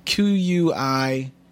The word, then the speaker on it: quixotic: Quixotic. – Well, it's like Quixote.